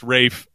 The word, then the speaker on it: Ralph: We've got two leading guys, and one of them denounces that pronunciation. So we may have run out of our tolerance for Ralph. I don't know. But I don't know how we, besides just Rafe, besides you just, that's her little dive on Rafe Fiennes, I guess, for the time being and the pronunciation.